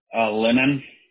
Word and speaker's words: Lenon: Lennon. – How do you spell that? – L-E-N-O-N.